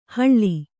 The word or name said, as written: ಹಳ್ಳಿ